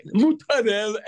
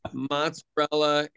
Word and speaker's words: mozzarella: And over the years, as things got mispronounced, because if you go order a sandwich in New York, what people claim to be Italian is not Italian. Mutarella and some gabagool is mozzarella and capicola.